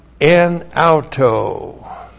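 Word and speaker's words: En auto (ἐν αὐτῷ): And then we have en auto. Preposition en plus the dative of autos, that's the dative form there. E-N-A-U-T long O. En auto.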